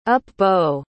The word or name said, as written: up-bow